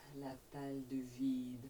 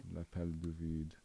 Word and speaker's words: L'appel du vide: L'appel du vide. – L'appel du vide.